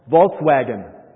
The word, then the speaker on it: Volkswagen: Volkswagen. – Volkswagen. Okay? It's Volkswagen. The V is pronounced like an F in German, and the W is pronounced like a V in German.